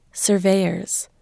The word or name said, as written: surveyors